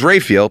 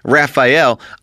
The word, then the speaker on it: Raphael: The only way I would say that he can't pronounce it is because she's got a name that people think how do you pronounce it wrong? Her name is Raphael. People pronounce it Raphael.